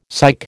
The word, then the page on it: psych: American English